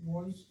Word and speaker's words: Reuss: That's the way most people seem to pronounce it. Royce, it's spelled R-E-U-S-S, though.